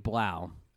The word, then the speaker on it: Blough: By the way, what's interesting, uh, Drew Lock and uh, David Blough you have to think of it. It rhymes with when the bow breaks.